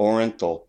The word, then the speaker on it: Orenthal: I thought it was Orenthal. Mm-hmm. – Orenthal is how you say it.